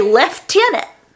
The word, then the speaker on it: lieutenant: The British say lieutenant, even though there's not an F in lieutenant.